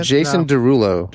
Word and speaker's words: Derulo: Yeah, Jason Derulo. – Is it Derulo? Am I overpronouncing it? – Jason Derulo. – Derulo. – Say it how it's Derulo. Jason Derulo.